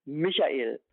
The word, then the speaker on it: Michael: Junge Namen sind kürzer, oft einsilbig, enden sehr oft auf einen Konsonanten und sind sehr oft, wenn sie mehrsilbig sind, auf der ersten Silbe betont. Michael.